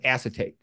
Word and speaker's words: acetate: So acetic acid becomes acetate.